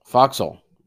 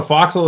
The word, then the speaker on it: forecastle: Foxhole. – It's called a foxhole. That's what I kept saying, but it's spelled forecastle, right? – Right, right.